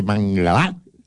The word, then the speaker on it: Mangalá: Y entonces yo en este caso creo que Garay puede hacer que Mangalá...